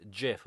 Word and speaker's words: GIF: and said it's not pronounced GIF. It's pronounced GIF with a hard G, not a soft G, a hard G, GIF, like the peanut butter.